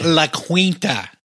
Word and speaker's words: La Quinta: It's pronounced La Quinta. – La Quinta, that's right.